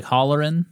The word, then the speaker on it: Holleran: Let me holler at you like Holleran? – Yeah.